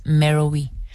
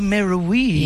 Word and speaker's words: Meroë: I've heard many pronunciations. – Yes. – I gravitate towards Meroë the most. – Oh, Meroë. – Yes. – Hence the double dots on the E.